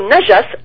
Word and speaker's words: najas: Et c'est sur le mot najas, N-A-J-A-S, impureté, que je voudrais insister. Parce que le mot najas en arabe, il existe également chez les chiites persans, en persan, et ça se prononce najes. N-A-D-J-E-2-S.